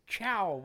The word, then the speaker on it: Ciao: Here's one called the Chow Wagon, but it's spelled C-I-A-O.